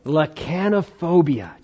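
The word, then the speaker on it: lachanophobia: You have a fear, and it's called, let me pronounce it correctly, lacanophobia. Lacanophobia.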